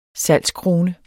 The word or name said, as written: salgskrone